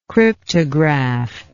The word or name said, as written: cryptograph